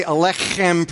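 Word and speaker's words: aleichem: In order to say alechem properly, you have to pretend you have a piece of matzah stuck in your throat and you're trying to get it, because the accent is on the kh in alech.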